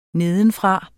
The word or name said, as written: nedenfra